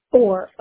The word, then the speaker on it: Orr: Orr, O-R-R.